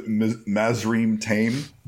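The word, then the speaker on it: Mazrim Taim: No, so Mazrim Tame. You said, like, Mandarin Tyne or something like that.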